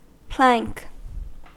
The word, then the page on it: plank: American English